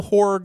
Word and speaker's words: horror: You gotta pronounce that second R. Horror. – Yes. Two syllables.